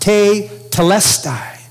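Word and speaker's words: te telestai (τετέλεσται): It's a phrase that is made up of one Greek word pronounced te telestai.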